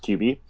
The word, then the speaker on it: Qbby: I think his name is pronounced QB.